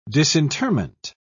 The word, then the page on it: disinterment: British English